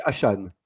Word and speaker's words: Achane: Il m'a dit, non, mais on dit Devon Achane. – Achane. – Donc, on dit Achane.